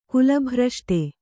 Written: ಕುಲಭ್ರಷ್ಟ

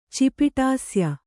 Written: ಚಿಪಿಟಾಸ್ಯ

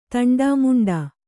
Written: ತಂಡಾಮುಂಡಾ

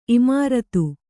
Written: ಇಮಾರತಿ

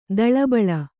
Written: ತಾಳೆಬೀಳು

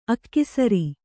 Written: ಅಕ್ಸರ

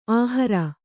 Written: ಆಹೇರಿ

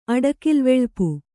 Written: ಅಡಕಿಲ್ವೆಳ್ಪು

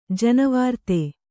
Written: ಜೈನವೃತ್ತಿ

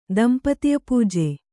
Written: ದಂಪತಿಪೂಜೆ

